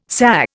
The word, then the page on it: SA: Indian